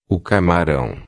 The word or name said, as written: camarao